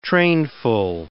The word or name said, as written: trainful